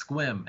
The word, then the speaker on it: Sequim: No, it's Squim, and I'm like, and I just laughed my ass off. I was like, I was like, really, all those letters, and it's just like one fucking syllable.